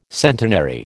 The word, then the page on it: centenary: American English